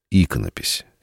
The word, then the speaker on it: иконопись: В первую очередь меня интересует иконопись. И, надеюсь, я правильно поставил ударение в этом слове. – Абсолютно правильно, да.